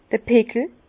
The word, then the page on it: d'r Pickel: Bas-Rhin; Herrlisheim